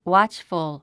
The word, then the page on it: watchful: wάtʃfəl; ウァッチフゥ